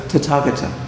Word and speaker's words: Tathagata: Tathagata.